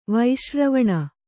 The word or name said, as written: ವೈಶ್ರವಣ